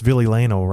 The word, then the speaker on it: Ville Leino: I guess Ville Leino rhymes with Billy Baino.